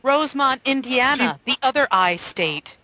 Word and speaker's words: Rowsmonte: but it was Rosemont, Illinois, between Poplar City and Crane Lake, spelled R-O-W-S-M-O-N-T-E. The E is silent.